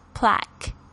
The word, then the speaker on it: plaque: 那这个 plaque，特别注意一下这个 q-u-e 的发音呢，是发 k 这个声音 plaque。